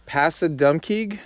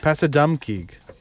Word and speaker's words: Passadumkeag: Passadumkeag? – Passadumkeag.